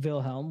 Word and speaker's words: Wilhelm: So I got Is it with a W? – It's with a W. So like, you know, like Dirk Nowitzki. So I got to say it like that. Okay. So Jacob and Wilhelm were born in a town in now modern day Germany.